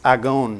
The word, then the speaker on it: agon: It's agon. What does that sound like? Agony. That's where we get our word agony from.